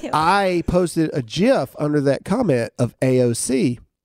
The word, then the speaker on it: GIF: The person who invented the GIF came out to end the debate finally and said it's pronounced like J-I-F. GIF.